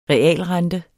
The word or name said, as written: realrente